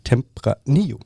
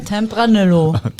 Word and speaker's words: Tempranillo: Die Aussprachepolizei, Tempranillo. Nein, der heißt einfach, man spricht die zwei L als J aus. So wie es auch nicht Mallorca heißt, sondern Mallorca.